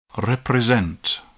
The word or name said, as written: represent